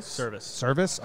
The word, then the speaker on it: Servais: Service.